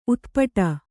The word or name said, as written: ಉತ್ಪಟ